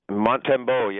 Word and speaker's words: Montembeau: But second of all, you got to help me out on your new goalie, Sam Montembeau. Am I saying it right? Sam Montembeau? – Montembeau, yeah.